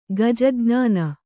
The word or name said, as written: ಗಜಜ್ಞಾನ